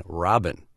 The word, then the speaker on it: Rabin: in Israel, they pronounce Rabin, Robin.